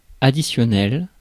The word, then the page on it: further: American English; [ˈfɝ.ðɚ]; /fɜː(r)ðə(r)/; /ˈfɜː.ðə/